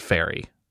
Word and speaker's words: Fieri: And when I say something like, hear me out, what if we make a Guy Fieri themed Fieri. – Although I believe it's actually pronounced fairy.